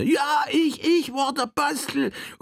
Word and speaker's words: Bastel: Jawohl, denn Sebastian wird auf Altenburgisch Bastel ausgesprochen.